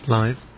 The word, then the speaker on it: Blyth: How's this spelled? – B-L-Y-T-H. – Blythe. – Blythe, is that it? – Blythe.